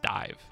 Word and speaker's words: DIIV: Horsehead by, is that D-I-I-V? – Yes, pronounced Dive.